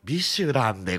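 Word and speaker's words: 美酒ラン: うちはあれですから、ね、お酒も美味しいですよ、はい、ミシュランではなく、ね、美しい酒と書いてビシュランでございます、ビシュランでございます。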